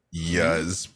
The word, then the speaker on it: Iuz: It's pronounced I-U-Z. So I would say Yuz probably.